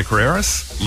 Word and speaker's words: Carreras: I think the S in Carreras is pronounced. Jose Carreras, Luciano Pavarotti and Placido Domingo.